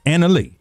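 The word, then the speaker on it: Analeigh: Is that how we pronounce her name? Annalie? – Alina Annalie. – Annalie.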